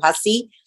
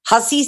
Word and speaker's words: Hussey: A Matthew lo pueden encontrar en Matthew Hassi. Hassi se escribe H-U-S-S-E-Y.